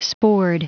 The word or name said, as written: spored